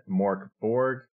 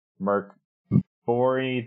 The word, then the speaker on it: Mörk Borg: So the game that we are going to be playing is, I'm going to pronounce it, Mörk, Borg. You could pronounce it Mörk, Bori. There's, probably a few other ways that people have pronounced this game over its lifespan, but we're going to call it Mörk, Borg.